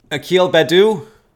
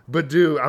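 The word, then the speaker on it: Akil Baddoo: This guy, Bamboo, I think that's how you say it. Let me say his name right. You know who I'm talking about? – Akil Badu? – Badu.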